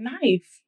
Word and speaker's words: knife: It's like knife. Why is the K there? We don't know, but it's there. You don't pronounce it.